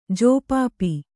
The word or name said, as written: ಜೋಪಾಪಿ